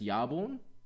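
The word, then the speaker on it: Jarnbjörn: So Thor doesn't have his hammer anymore, and he gets, I believe it's pronounced Yarborn. Yarborn is his first weapon, well before he had the hammer, and it's a big battle axe.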